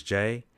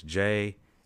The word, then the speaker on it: Jay: My name is Las Vegas J. You can follow me on YouTube and Instagram at Las Vegas J, and the J is spelled with J-A-Y.